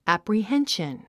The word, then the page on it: apprehension: æ`prihénʃən; アプリヘンション